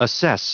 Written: assess

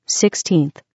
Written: 16th

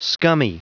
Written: scummy

